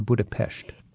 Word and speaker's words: Budapest: first of all, is it Budapest or Budapest? – It's Budapest. – So typically Western people say Budapest. – Yes, but the Hungarian way of pronunciation is Budapest.